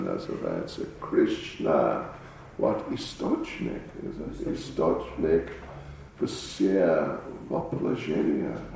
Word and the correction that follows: воплощения: How do you say it? Vaplashenia. Vaplashenia.